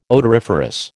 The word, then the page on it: odoriferous: American English